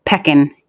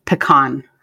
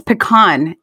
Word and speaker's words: pecan: I said the word pecan instead of pecan. Y yo no sé por qué nadie le dio por decirme, hey, you know, that's not how you pronounce pecan. It's pecan.